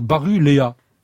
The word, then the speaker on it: Baruella: C'est impossible, ils n'arrivent pas à dire Baruella. C'est pourtant très simple. Eh bien non, il y a 4 syllabes, il y en avait peut-être 2 de trop.